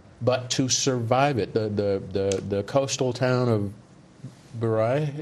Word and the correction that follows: Beira: is that how you pronounce it in Mozambique? – Beira.